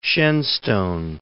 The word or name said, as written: Shenstone